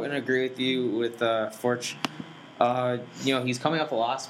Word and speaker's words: Forte: Henny Forch, I believe is how you pronounce Henny, it. – Yeah, Forch, you're right.